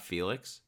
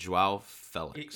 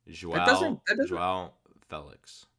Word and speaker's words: João Félix: João Félix, it's Félix. He said in a press conference, it's not Felix. It's João Félix.